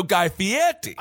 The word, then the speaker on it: Fieri: And unfortunately, after my years of protesting, it's starting to turn into, I'm starting to become a pro Guy Fieri. – Yeah, and you're still not saying the name right, though, Guy Fieri. – I will not. – Okay. – I will not give him the Italian lilt, but I will give him the benefit of the doubt that he has not touched anyone against their consent.